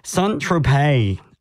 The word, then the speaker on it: Saint-Tropez: At the top, we heard from Sunshine Coast Indie Rockers, who have a name that I've been pronouncing very, very wrong. Shout out to Kobe from the band who texted in with their correct pronunciation. Saint-Tropez. It never crossed my mind that it is actually a French word, so I'm a silly goose for that.